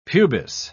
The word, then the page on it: pubis: British English